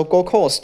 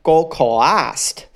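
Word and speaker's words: Gold Coast: 我那时候 Go Coast 的发音还是 Go Coast，我还不知道 C O A S T 的 A 是不发音的。我那时候真的觉得自己还蛮愚蠢的。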